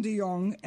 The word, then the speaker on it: DeYoung: The spotlight was with Gary Williams, Brendan DeYoung. Am I pronouncing his name right? – Yes, you are.